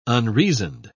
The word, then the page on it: unreasoned: British English